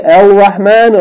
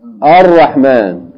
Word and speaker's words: ar-Rahman: Like we say, do we say al-Rahman or ar-Rahman? Right? Naam. We don't say al-Rahim. We say ar-Rahim.